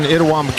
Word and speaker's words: Itawamba: And Neil, the folks in Itawamba County would really appreciate your pronunciation, making that I and E, because at home in Itawamba County, that's what they say.